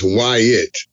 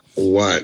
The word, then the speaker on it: Wyatt: And it's Wyatt, not Watt, but it's okay.